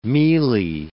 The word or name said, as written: mealy